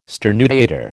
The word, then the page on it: sternutator: American English